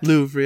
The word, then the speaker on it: Louvre: Is that how you pronounce that? – Louvre, yeah.